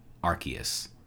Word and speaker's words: Arceus: The correct pronunciation is Arceus.